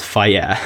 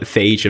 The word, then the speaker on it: FAGE: And now let's look at real low-fat Greek yogurt, such as the 0% FAGE, which if you didn't know is actually pronounced fire, but some people call it phage and fake and has many different names.